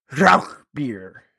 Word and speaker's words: Rauchbier: It's actually pronounced Rauchbier. I think I did it right. You try it. – Rauchbier. – Rauchbier. It's very gruff.